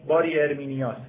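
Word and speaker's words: باری ارمینیاس (Περὶ ἑρμηνείας): باری ارمینیاس. حالا دیگه چیزشه دیگه، یعنی تلفظ یونانی، تاکید میکنم یونانی، لزوما لاتین ندید،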